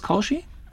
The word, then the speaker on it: Kaushi: Genau, und der Autor ist Bekim Kaushi, spricht sich das Kaushi? – Genau. – Also mit CAU geschrieben.